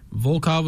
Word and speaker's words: Volkov: Tell me if I'm wrong, Tai Tuivasa and Alexander Volkov. – Volkov was correct.